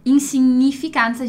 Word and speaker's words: insignificanza: A festa da insignificância, enquanto em italiano, insignificanza, a gente vai ler o GN como NH, né?